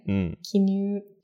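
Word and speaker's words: 金融: Ki and then nyu. – I feel like commonly it's pronounced kinyu. Like the ni and the u is separated. But kinyu.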